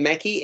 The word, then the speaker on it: Mackey: A role filled, do you pronounce her name Mackey, M-A-C-K-E-Y, Emma Mackey? – Yes, that's what Rob said, yeah.